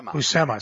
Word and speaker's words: Buscema: It was Stanley wrote it, and John Buscema. Am I saying that right? – Buscema.